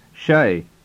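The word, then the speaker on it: شاي: The word for tea is pronounced shay in certain regions of Saudi Arabia.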